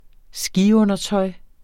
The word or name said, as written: skiundertøj